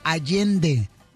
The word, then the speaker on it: Allende: Se le acerca una señora y dice, no se dice Ande, el señor Allende.